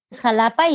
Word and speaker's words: Xalapa: Sí, la capital de Veracruz es Xalapa y se escribe habitualmente con X, pero es por el sonido de la X.